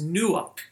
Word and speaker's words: nuakh: is nuak, and you can spell that N U A K H, and it means to settle in.